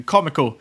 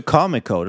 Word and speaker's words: Cameco: Efter det här så kom även Cameco ut med earnings. Och de här två tillsammans är ju Det är ju majoriteten av uranproduktion. – Säger du Cameco? Det låter som Comic-Con. Jag brukar säga Cameco.